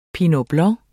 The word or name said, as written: pinot blanc